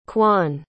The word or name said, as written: quon